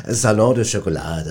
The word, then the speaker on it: Salon du Chocolat: Ja, das Salon du Chocolat, das hast du schon ganz schön ausgesprochen, schon ganz richtig.